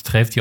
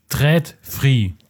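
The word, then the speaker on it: TRÅDFRI: Was ich aber noch gefunden habe bei den Ikea Apps, heißt Ikea Treftri und bei dem, also ich denke mal, dass es Treftri ausgesprochen wird, weil, nee, Tread Free. Und bei dem A ist so ein Kreis drüber. Also Tread Free.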